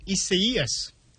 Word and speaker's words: Isaias: The correct pronunciation of the hurricane, it's Hurricane Isaias.